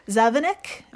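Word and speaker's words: zavináč: So in Czech, the Czech Republic, it's pronounced Zavinek, meaning roll mop or pickled herring.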